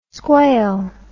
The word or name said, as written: squail